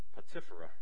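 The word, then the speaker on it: Potipherah: And unto Joseph were born two sons before the years of famine came, which Asenath, the daughter of Potipharah, let me try to say that right, it's split up in my book, Potipharah, priest of On, bare unto him.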